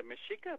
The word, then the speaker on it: Mexica: Now, in the Mexica movement, by the way, Mexica is spelled M-E-X-I-C-A, like Mexico, but uh-huh. In the Nahuatl language, the X is pronounced with an S-H sound.